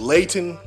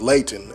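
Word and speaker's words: Leighton: His name is Layton Flowers. And I want to spell that name right. It's L-E-I-G-H-T-O-N. It's Layton and then Flowers, you know, F-L-O-W-E-R-S.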